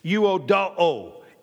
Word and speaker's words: Euodoo: Euodoo. E-U-O-D-O-O. Euodoo. They always make you pronounce both all the letters. They say Greek is the most honest language in the world because you have to pronounce there are no silent letters in it. Uodo. Well, how about that E at the beginning of it? Euodoo.